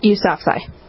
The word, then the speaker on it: Yousafzai: Yousafzai.